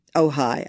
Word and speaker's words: Ojai: They are in, I'm not sure if I'm pronouncing it correctly, but Ojai, California. – Ojai. I happen Ojai. – To know that.